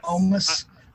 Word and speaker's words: Omos: Yeah, see, I'm kind of glad that I watched some of the highlights because Omos, that's how you say it? – Yes. – Omos. – We've been saying it wrong the whole podcast. – Famous Amos cookies. – No, it's definitely not Amos.